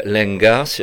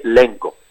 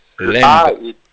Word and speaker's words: Lenga: Mais un occitaniste vous dirait Lengo. – Lengo. – Le A est occlusif à la fin. – Alors je dirais Lengo, alors.